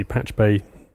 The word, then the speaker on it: Pachube: So Patch Bay, we'll see Patch Bay, pronounced Patch Bay, but not spelled like that way.